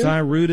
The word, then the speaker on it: Cyruta: There is a supplement called Cyruta Plus. Let me write that down here. Cyruta. – Thank you. – Cyruta is spelled C-Y-R-U-T-A. Cyruta. – C-Y-R-U-T-A.